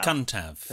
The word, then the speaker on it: Cyntaf: Cuntav. – Perfect. – That's the first on the podcast. – If Jordan wins... – Jordan will be cuntav. – Yes, I've said it for years. – Absolutely. And it's spelled C-Y-N-T-A-F, in case you were to want to write that down.